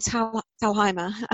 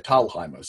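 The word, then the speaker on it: Thalheimer: I spent years pronouncing Will's name wrong before he told me, no, it's actually pronounced, it's the German way, Talheimer. So yes, you pronounce it right.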